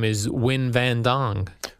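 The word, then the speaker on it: Nguyen: His name is, it's that name which I see in foreign countries sometimes that's spelled his first name, N-G. N-G-U-Y-E-N. – Oh, Win. – And his last name is, oh, that's how you pronounce that, Win?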